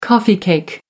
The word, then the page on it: coffee cake: American English